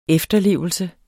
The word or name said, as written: efterlevelse